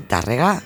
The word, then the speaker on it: Tárrega: Provenientes de Les Terres de Lleida, en Tárrega, y de Barcelona. – Mira qué bien lo has pronunciado y todo, ¿eh? – Como Cristina Tárrega.